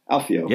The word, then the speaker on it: Alfio: Alfio. Am I saying that right? Do you think Alfio? – How do you spell that? – A-L-F-I-O. Alfio.